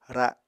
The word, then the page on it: ra7: Apatani